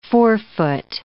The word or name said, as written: forefoot